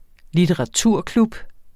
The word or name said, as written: litteraturklub